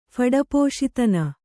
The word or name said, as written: ಫಡಪೋಶಿತನ